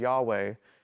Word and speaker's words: Yahweh: The Yah, Ha, and this is the V and the W are kind of, they get modern, it's V, actually ancient, it was actually a W sound. So you have here the YHV/WH, which is what this is, Yahweh.